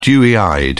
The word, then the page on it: dewy-eyed: British English